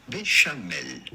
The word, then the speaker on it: béchamel: How do you go about pronouncing it in French? Because this is certainly a French word. This would be pronounced béchamel.